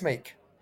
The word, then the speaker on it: Meeke: It's Chris Meek. Chris Meek. That's Chris spelt with a K. Meek is spelt M-E-E-K-E.